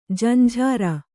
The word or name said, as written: ಜಂಝಾರ